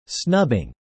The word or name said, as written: snubbing